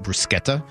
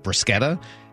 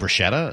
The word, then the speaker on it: bruschetta: so she always will pronounce it bruschetta because that's the correct pronunciation of it, bruschetta. And so then people will correct her and be like, oh, you mean bruschetta?